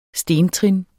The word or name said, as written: stentrin